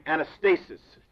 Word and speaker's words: anastasis: It is the word in the Greek which we would pronounce, not this way in the Greek, anastasis.